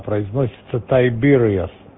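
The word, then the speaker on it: Tiberias: Ну, это так пишется, а произносится Тайбериас.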